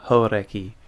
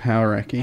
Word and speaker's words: Hauraki: I'm glad you've said that correctly, because that's been a real push about saying Hodaki correctly. – Instead of Hauraki.